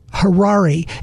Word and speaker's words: Harari: His name is Noah Yuval Harari. It's almost like Ferrari. It's spelled H-A-R-A-R-I.